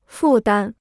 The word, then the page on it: 负担: fù dān